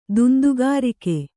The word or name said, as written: ದುಂದುಗಾರಿಕೆ